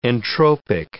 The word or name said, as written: entropic